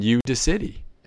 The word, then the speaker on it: Udacity: I guess it's supposed to be pronounced Udacity, UDACIT. Again, a play on I guess audacity, but it's like Udacity.